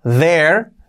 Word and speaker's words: there: Para expresar simplemente a ver, pues tenemos que unir esta palabra there, there, there, es como allí, there, importante la pronunciación, que no sea there ni nada por el estilo, there, there. There, there, there.